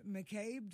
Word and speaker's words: McCabe: That was a quote from Dr. Brett McCabe. Did I pronounce his name right? – That's correct. That's correct.